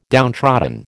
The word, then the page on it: downtrodden: American English